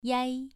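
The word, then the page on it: yai: tone 2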